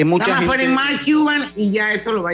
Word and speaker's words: Cuban: Se escribe Cuban, como si fuera Cuban, como cubano.